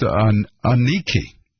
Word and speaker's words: Aniki: Her name is pronounced Aniki. – Aniki? – Like UN-KNEE-KEY. – Aniki. – Yeah, wow. It must be Aniki, or it could be, yeah, it's got to be like, with emphasis on the first syllable.